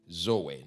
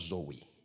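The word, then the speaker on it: Zoe (ζωή): Please don't pronounce it like Americans. This is Greek. Zoe, not Zoe.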